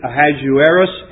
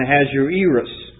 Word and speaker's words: Ahasuerus: In the first year of Darius, the son of, it's pronounced two ways, Ahasuerus and Ahasuerus.